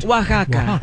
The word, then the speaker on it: Oaxaca: When in all actuality is pronounced Oaxaca.